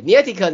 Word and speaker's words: Niantic: 你 Niantic，Niantic 对 Niantic 呢跟高通合作，呃，要打造出一个 AR 头盔的范本，让人可以在真实的世界跟虚拟做结合，然后玩游戏，然后会结合。那个 Niantic